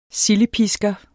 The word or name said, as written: sildepisker